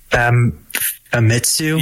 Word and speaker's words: Famitsu: I'm going to butcher this, but Famitsu. – Yeah, I think that's right.